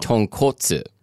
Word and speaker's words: tonkotsu: So wait, I get very confused pronouncing it because I know also that, like, the ramen, like rich pork broth is called tonkatsu. – Tonkotsu. – Okay, so you pronounce it with, like, a longer O sound. – Or like a more rounder O sound.